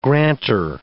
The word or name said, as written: granter